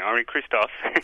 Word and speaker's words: Henri Christophe: By the way, you did pronounce it correctly, Henri Christophe.